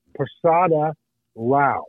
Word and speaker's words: Prasada Rao: But his last name is spelled P R A S A D A space capital R A O Prasada Rao, and Rao is a separate name.